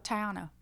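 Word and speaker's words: Taíno: The indigenous group that occupied this area, which is Jamaica, was, I believe it's pronounced the Taino. – Oh, Taiano. – Is it Taiano? – Mm-hmm. – Ah? – Mm-hmm. – Okay. – Yeah. – Taiano. It doesn't, it's hard to, because phonetically, that's not how it's written.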